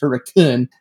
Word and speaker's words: Hurricane: It's spelled Hurricane, but you can't pronounce it that way. It's Hurricane.